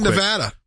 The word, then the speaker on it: Nevada: All right, Rick in Nevada.